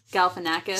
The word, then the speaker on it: Galifianakis: How do you pronounce his name? – Galifianakis.